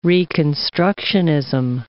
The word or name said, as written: reconstructionism